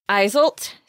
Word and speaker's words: Iseult: It's about a young woman named Iseult. So the author told me, Molly Polig told me that it's pronounced like insult without the N, which is kind of hard to say, like to wrap your mouth around it when you think about it.